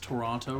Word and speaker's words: Toronto: Also, did you know we pronounced Toronto wrong? – How is it pronounced? – It's Toronto. – Toronto? – Yeah. Like, the O doesn't exist.